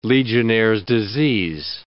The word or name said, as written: Legionnaires' disease